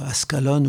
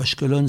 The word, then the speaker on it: Ashkelon: Il y a des villes comme Ascalon ou Ashkelon, selon qu'on prenne la prononciation palestinienne ou hébreu.